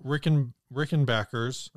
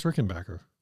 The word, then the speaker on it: Rickenbacker: At least two Duesenbergs and two Rickenbackers. – Right. It's pronounced Rickenbacker. – That was Rickenbacker.